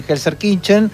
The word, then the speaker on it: Gelsenkirchen: Terminó 2-2 en un amistoso jugado en Gelsenkirchen, en la ciudad alemana.